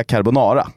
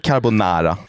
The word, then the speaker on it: carbonara: Men jag tycker inte att man säger carbonara. – Men då måste man ju göra alla andra uttal helt keffa också. – Ja. – Bolognese. – Ja, exakt. – Man säger bolognese. – Men liksom så här carbonara, alltså du är ingen jävla international. – Nej. – Det heter carbonara. Om du inte är Italien.